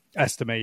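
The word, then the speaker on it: Estimé: Estime, yeah. Notre Dame. – Estime.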